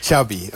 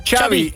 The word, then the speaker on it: Xabi: Xabi, okay. – Yeah. – So I heard the Xabi like chocolate. – Yes, okay, thank you very much. – Wij deden het al goed. – Xabi, ja, Xabi.